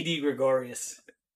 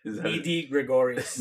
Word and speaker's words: Didi Gregorius: It's probably that guy, Didi DeGregorius. – Didi Gregorius. You got me tongue twisted here. Didi Gregorius. – Didi DeGregorius. – No, Gregorius.